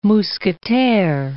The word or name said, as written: Mousquetaire